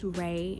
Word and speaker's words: Raii: And if you want to, follow me on Instagram or whatever, it's IGOBYRAII, which is spelled I Go By Ray. And yeah, let's go ahead and get into it. So my name is Ray, and I am an upcoming artist.